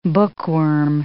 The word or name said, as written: bookworm